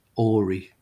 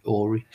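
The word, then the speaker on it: Ury: It's by Roger Fisher and William Ory, U-R-Y. I think that's how you pronounce his name, Ory.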